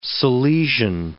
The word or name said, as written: Salesian